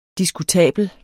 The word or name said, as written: diskutabel